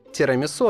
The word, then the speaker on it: тирамису: Но в 2012 году тирамису однозначно и официально получил ударение на последнем слоге.